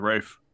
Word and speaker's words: Ralph: That was Rafe. Rafe. – I don't know. It's it's, it's, weird. Yeah, no, his name is pronounced very strange from how it's spelled.